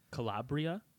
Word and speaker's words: Calabria: Calabria? – Yeah.